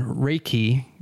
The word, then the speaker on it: Reiki: But it's Alchemist's Heir, H-E-I-R, .com. So his book is described as... distilled in Reiki. Am I saying that right? Reiki. – Yeah. – Reiki. – Well, okay. I don't know why I just answered. That's well, I mean, you can answer too, I guess. – I think I know what that is. I think it's pronounced Reiki.